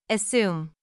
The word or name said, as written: assume